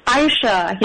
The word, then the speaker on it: Ayesha: And it's It's spelled like A-Y-E-S-H-A, so most people will pronounce it Aisha.